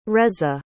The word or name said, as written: reza